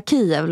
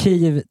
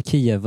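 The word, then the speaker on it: Kyiv: Jag har gått från Kiev till Kiev, för jag trodde Kiev var det som var rätt. – Det är det som är ryskt uttal. – Kiev är det ryska uttalet.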